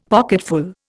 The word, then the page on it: pocketful: Indian